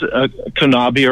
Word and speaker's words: CONAB: Yeah, and the big debate is because CONABI, or a lot of people pronounce it CONAB,